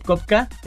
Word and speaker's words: Copka: Me pueden encontrar en TikTok y en Instagram como Rob Kopka. Rob es mi nombre, Roberto. Kopka me pedido, se escribe con C-O-P-K-A.